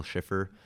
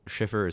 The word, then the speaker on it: Schiffer: Daniel Schiffer. Schiffer is spelled S-C-H-I-F-F-E-R.